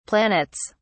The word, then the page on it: planets: British English